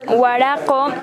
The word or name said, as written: Waraq´o